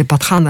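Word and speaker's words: Pathaan: Вот, и потому что, допустим, вышел, вот когда я была, в те дни состоялась национальная премьера фильма, нового фильма с участием Шахрухана, «Патан», или «Патхан» они произносят,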